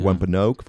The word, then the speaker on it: Wampanoag: de Wampanoag. – Wampanoag. – Ja. Wampanoag wordt het uitgesproken in het Amerikaans.